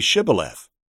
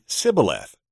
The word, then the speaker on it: Shibboleth: If they said no, they would tell them, "Then say Shibboleth." But he would say Sibboleth because he couldn't pronounce it correctly.